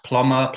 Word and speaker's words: Plommer: Um, and then my Instagram is just literally just my name, Sam Ploma. Sam Ploma, that's uh, P L O M M E R. Yes, Ploma, Ploma, however you feel like pronouncing it.